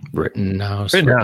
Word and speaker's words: Rittenhouse: And that's where we get into this Kyle Felt written. I'm having a I'm not sure how to pronounce it. – Kyle Rittenhouse. Is that what it is? – Rittenhouse.